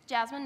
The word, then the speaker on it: Jasmine: Jasmine Noble. – Jasmine Noble. – Is there a problem? – How do you spell that? – J-A-S-M-I-N-E Noble. L-E, not E-L.